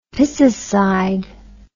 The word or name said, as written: piscicide